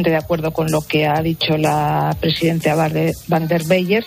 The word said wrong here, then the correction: von der Leyen: si lo he pronunciado bien, que soy incapaz de pronunciar ese apellido. – Pues no, es von der Leyen.